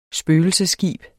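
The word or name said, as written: spøgelsesskib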